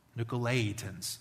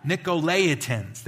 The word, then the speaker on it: Nicolaitans: Nicolaitans. I've tried to do that for a week and I can't say it right. Nicolaitans. There we go.